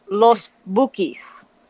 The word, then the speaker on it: Los Bukis: Can we teach you how to say it correctly? – How do you say it? – To not anglicize it? – Yeah, go ahead. – You say it. – Los Bukis. – Los Bukis. – That's what I said. – Not really. – No, you said Los Bukis. It's Los Bukis.